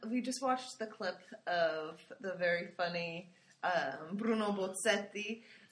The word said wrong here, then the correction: Bozzetto: and. What? – Bozzetto. – Bozzetto. – Bozzetto. – Bozzetto. Grazie. And he was had a funny animation of little misunderstandings between the pronunciation of English and Italian.